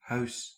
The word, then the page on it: maison: /mɛ.zɔ̃/